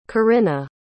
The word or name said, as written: Corinna